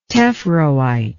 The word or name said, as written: tephroite